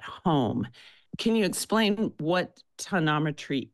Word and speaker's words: tonometry: And you monitor it through something called tonometry. Did I get the correct pronunciation of it? – Yes. – Tonometry. – Yes.